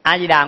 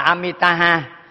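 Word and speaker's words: Amitābha: A Di Đà nguyên đúng nguyên âm tiếng Phạn là Amitaha, mà chúng ta phát âm là ba chữ là A Di Đà, nó quen rồi, không?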